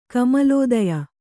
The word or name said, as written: ಕಮಲೋದಯ